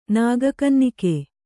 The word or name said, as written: ನಾಗಕನ್ನಿಕೆ